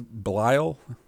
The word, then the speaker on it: Belial: So I will say, so his name is Belial? – Yes.